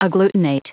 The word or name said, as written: agglutinate